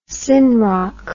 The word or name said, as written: synroc